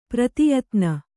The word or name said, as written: ಪ್ರತಿಯಾತನ